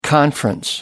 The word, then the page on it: conference: American English